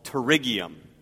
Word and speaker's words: pterygium: Ophthalmology, the study in medicine of the eye, specifically the serious medical disorder called pterygium. It's spelled with a P in case you want to Google it later.